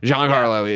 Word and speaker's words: Giancarlo: And I'm saying that wrong, aren't I? – It's Giancarlo. – Giancarlo is like an awesome actor.